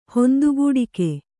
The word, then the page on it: ಹೊಂದುಗೂಡಿಕೆ: Kannada